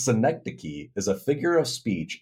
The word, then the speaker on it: synecdoche: Synecdoche.